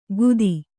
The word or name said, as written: ಗುದಿ